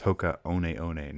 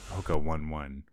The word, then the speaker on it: Hoka One One: Or sorry, I guess last weekend was the there was a half marathon here, sponsored by Hoka, the shoe brand, which I believe is pronounced Hoka One One, not Hoka 1-1, which is how it's spelled.